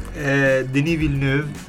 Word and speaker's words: Denis Villeneuve: Denis Villeneuve, ecco, grazie.